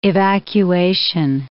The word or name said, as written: evacuation